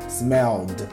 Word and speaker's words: smelled: Lembrando que ed no final do verbo não necessariamente é uma sílaba, ok? Em alguns casos sim, mas na maioria não. Então, smell, smelled, tem esse som de d no final, né?